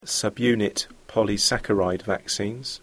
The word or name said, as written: subunit polysaccharide vaccines